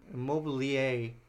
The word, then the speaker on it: Immobile: Immobile was or Immobili- Immobilier is the way to pronounce his actual name.